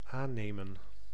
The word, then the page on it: aannemen: /ˈaː